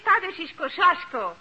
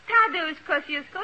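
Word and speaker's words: Tadeusz Kościuszko: In Poland, we say his name Tadashish Kosciuszko. But in America, Thaddeus Kosciusko is fine.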